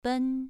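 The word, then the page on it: ben: tone 2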